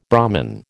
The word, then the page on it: Brahmin: American English